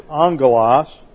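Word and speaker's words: angelos (ἄγγελος): Now the Greek word is angelos, A-N-G.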